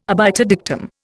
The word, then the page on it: obiter dictum: Indian